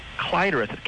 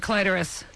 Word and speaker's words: clitoris: Clitoris is the first, but here's the thing that Kathy might be interested in, because she mentioned it earlier. – And I have one. – The secondary pronunciation is clitoris. – Get out of here. You're making that up. – That's because the English word comes from a Greek word originally, clitoris, K-L-E-I-T-O.